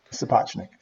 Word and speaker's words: Sapochnik: Sapochnik.